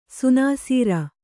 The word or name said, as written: ಸುನಾಸೀರ